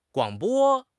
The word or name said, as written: guang3 bo1